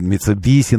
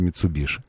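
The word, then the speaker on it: Mitsubishi: Эти споры бесконечные, которые до сих пор продолжаются о Митсубиши, что это Митсубиси на самом деле, хотя Ши, конечно. Несмотря на то, что Ша буквы нет в японском, но все равно произносится Митсубиши.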